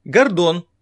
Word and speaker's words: Гордон: Гордон. Александр Гордон. Работал вместе со мной на «Серебряном дожде». Я все время говорил ему, что ударение в его фамилии надо делать на первом слоге.